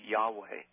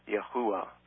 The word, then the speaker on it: Yahweh: Now, if the vowels are supplied, the pronunciation is something close to Yahweh, or it could be Yahuwah.